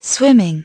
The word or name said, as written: swimming